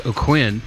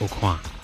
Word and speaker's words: O'Quinn: My family says O'Quinn, which is pretty normal, but I think if we were actually Cajun or French, we would say like O'Quinn.